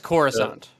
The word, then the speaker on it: Coruscant: with as far as like is it Coruscant, is that how you pronounce the yes, Coruscant,